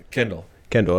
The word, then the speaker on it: Kendall: we used to, so Bob, is it Kendall or Kendall? How do you say your last name? – Kendall.